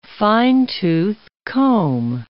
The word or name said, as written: fine-tooth comb